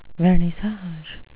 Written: vernissage

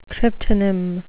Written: cryptonym

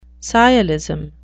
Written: sciolism

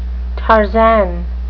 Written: tarzan

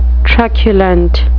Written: truculent

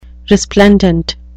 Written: resplendent

